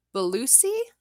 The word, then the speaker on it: Bellucci: We've got Monica Bellucci. B-E-L-L-U-C-C-I. – Very good. – Bellucci, I think that's right. – I think you nailed it.